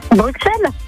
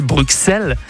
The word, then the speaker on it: Bruxelles: Alors, il paraît qu'on doit dire Bruxelles. – Et que je sais pas. – Et que le X, il ne se prononce pas que ce. Les Belges, ils n'aiment pas trop quand on dit Bruxelles, mais plus Bruxelles.